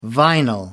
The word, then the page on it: vinyl: American English